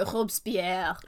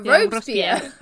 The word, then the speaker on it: Robespierre: Because my inclination is to go Robespierre, right? Like properly French. They go Robespierre. Robespierre. And it's like, I know that that's technically an English pronunciation of it, but it sounds so wrong.